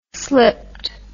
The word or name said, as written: slipped